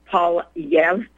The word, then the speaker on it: Poilievre: Everyone in England or in English pronounces it Poilievre,